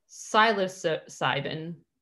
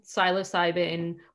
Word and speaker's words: psilocybin: So this psychedelic component in mushrooms is called psilocybin. I hope I said that right. There's a silent P at the beginning.